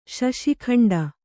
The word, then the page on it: ಶಶಿಖಂಡ: kannada